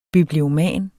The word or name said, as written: biblioman